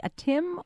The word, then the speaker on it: Atim: And we have – looks like a Tim or a team. Don't want to overpronounce. In Frisco, hi. – Hi. It's Atim, actually.